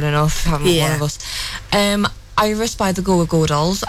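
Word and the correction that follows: Goo Goo Dolls: I actually thought it was Goo Goo when I was a kid. – It is Goo Goo? It's two O's. Goo Goo.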